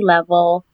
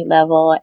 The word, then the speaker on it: Level: Instagram is The Brittany Level. Twitter is Brittany Level. And of course, on Facebook, it's Brittany Level. And it's spelled B-R-I-T-T-N-Y. And my last name, Level, L-E-V-E-L.